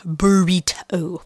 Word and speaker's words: Burreato: It's Crafty Burrito, and it's spelled B-U-R-R-E-A-T-O. Burrito, like you eat it, which is very cute because who doesn't love eating burritos?